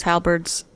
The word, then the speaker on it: halberd: When Gary was talking about those halberds on the fireplace, I wanted to be like, it's pronounced halberd.